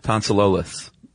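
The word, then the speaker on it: tonsillolith: Tonsilolith. – Liths. Like it ends in L-I-T-H-S.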